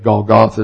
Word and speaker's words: Golgotha: Golgotha is the way most English speakers pronounce it.